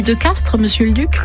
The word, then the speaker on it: Castries: Quand on parle de village de Castries en langue d'oc, dans la vieille langue du pays, on prononce Castres. Et comme au XVIIe siècle, cette langue était beaucoup plus parlée ici que le français, on a conservé l'usage de la prononciation ancienne.